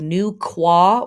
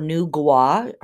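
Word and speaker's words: Nüwa: Also on this day, from our friends and ancestors in China, we have the day of Nu Kwa or Nu Gua. Her name is pronounced both ways.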